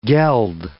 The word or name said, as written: geld